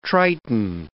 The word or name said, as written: triton